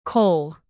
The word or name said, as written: kohl